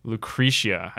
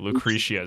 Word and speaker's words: Lucretia: Lucretia, I think is how you say it. Lucretia is how you say it.